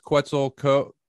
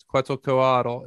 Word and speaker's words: Quetzalcoatl: Yeah, sometimes it's pronounced just Quetzalcoatl. Is how I've heard Latino speakers pronounce it. So maybe it's the American in me that puts the AL on the end, but that's how I always heard it before that. But yeah, it's Quetzalcoatl is how I know him as.